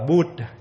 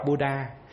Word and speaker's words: Buddha: Còn Buddha nó đọc là Bud chứ không có đọc Buddha.